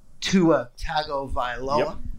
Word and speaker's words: Tua Tagovailoa: Trading up to get Tua Tagovailoa from Alabama. – Well pronounced.